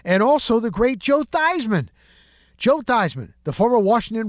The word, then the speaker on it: Theismann: When he went to Notre Dame, the wherewithal, the foresight, his name was Joe Theismann out of New Jersey, and he changed his name to Theismann to rhyme with Heisman, trying to get that Heisman trophy.